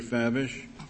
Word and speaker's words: Favish: Mr. Favish, am I pronouncing your name correctly? – Yes, Chief Justice.